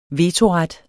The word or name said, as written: vetoret